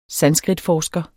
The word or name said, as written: sanskritforsker